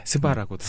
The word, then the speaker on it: SVA: 네, 스바라고. – 스바. 아, 스바라고 실제로. – 스바라고 불러요? – 굉장히 로컬적인 지금 네.